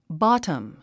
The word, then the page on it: bottom: bɑ’təm; ボォタム